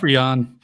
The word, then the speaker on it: Rian: Rian Johnson in this kind of situation. – But sorry, it's pronounced Rian. – Oh, I'm sorry, Rian.